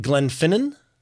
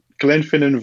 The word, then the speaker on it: Glenfinnan: Is it Glenfinnan? – Yes. – Glenfinnan? – It's the Glenfinnan Viaduct, and the train is the Jacobite Express.